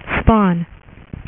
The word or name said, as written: Spawn